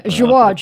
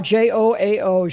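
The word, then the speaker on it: João: Joao, Joao, Joao.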